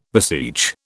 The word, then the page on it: beseech: British English